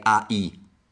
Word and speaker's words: I: Allora, I, sarebbe come io, I. Se io scrivesse in italiano, tra parentesi, la pronuncia è qua, direi AI, I.